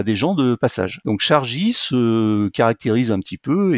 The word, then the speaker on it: Shargy: Et là, on a par exemple le groupe Volkswagen qui qui vient de lancer une application qui s'appelle Chargy. ça s'écrit S H-A-R-G-Y. Je sais pas exactement comment ça se prononce, mais c'est pas un C au début, c'est un S.